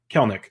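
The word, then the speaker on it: Kelenic: Jared Kelnick. And I found out that's how you say it today. I've been saying it wrong this whole time. Kelnick.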